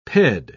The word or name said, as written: ped